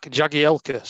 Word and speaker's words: Jagielka: It breaks my heart is Jack Zagielka. Zach Jagielka, say that correctly, put my teeth back in.